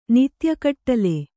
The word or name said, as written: ನಿತ್ಯಗಟ್ಲೆ